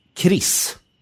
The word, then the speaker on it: KRIS: Så, och du identifierar då framförallt Broderskapsrörelsen och KRIS. – Mm. – Uttalas det så? – Ja, det gör det.